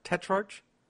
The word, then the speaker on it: Tetrarch: Tetrarch? – Tetrarch. So it's kind of like if the CH was a K, Tetrarch.